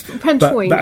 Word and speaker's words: Pentwyn: So I went to, like, Wales, and because one of my best friends is from Pentwyn? – Mm. – Yeah? Did I say that right? – I always saw it as Pentwyn, but Pentwyn. – Well, that's Pentwyn. Okay, well, you're Welsh, so you would know better than I.